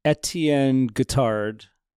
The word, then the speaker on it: Etienne Guittard: Etienne Guittard. That, am I saying that right? – Yes. Yep.